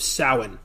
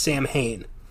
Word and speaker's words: Samhain: It began with the Celtic festival of Samhain, which many people think is pronounced Samhain.